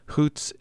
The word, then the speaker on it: Xóots: Xóots is pronounced with a raspy H sound and written in Tlingit orthography with the letter X.